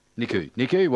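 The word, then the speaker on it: Nikou: I mean, we've got Chris Nicau, who's the chair nowadays. How do you pronounce his name? Nicau, is it? – Nicu.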